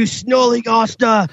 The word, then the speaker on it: snollygoster: You snollygoster! That's what it's called. Get off my lawn! – It is spelled S-N-O-L-L-Y. G-O-S-T-E-R, exactly mm-hmm. how it sounds, snollygoster, yep.